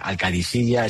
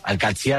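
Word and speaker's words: Al-Qadsiah: Bueno, se suele decir Al-Qadsi, pero también hay gente que dice Al-Qadisiyya. Es que es difícil, ¿eh? Ah, no es fácil, pero Al-Qadsi es lo más fácil.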